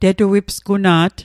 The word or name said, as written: tetuipsguna't